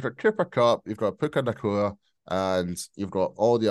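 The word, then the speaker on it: Puka Nacua: We've got Puka Nakua. How'd you pronounce his second name? – Puka Nakua. – Puka Nakua. All right, there we go.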